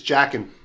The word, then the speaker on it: Joaquin: The way that Joaquin Phoenix played. That's how you pronounce his name, right? – Yeah. – Okay. – It's Jackin.